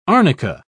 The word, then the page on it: arnica: British English